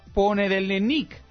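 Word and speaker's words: Ponedelenik (понедельник): Lunes, Ponedelenik.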